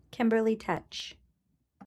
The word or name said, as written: Kimberly R. Tecce